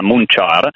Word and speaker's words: Munchar: Vabbè, Montechiaro in dialetto locale si chiama Munchar,